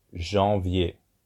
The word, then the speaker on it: janvier: January is pronounced janvier. Janvier.